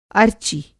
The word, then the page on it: अर्चि: Hindi